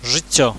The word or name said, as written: zyccio